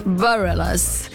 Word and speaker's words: Bareilles: Bareilles.